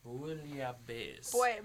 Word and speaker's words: Bouillabaisse: It's called, pardon my pronunciation, Boulabaisse? Boulabaisse? – Let me look at this. – Bouillabaisse. – Bouillabaisse. Okay. – Bouillabaisse.